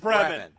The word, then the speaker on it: Bremen: It's Bremen. – Bremen. And you're close with the way you were saying it.